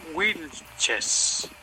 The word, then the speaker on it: Wilches: Bueno, el apellido se pronuncia Wilches y se escribe con W o con W, como dicen ustedes.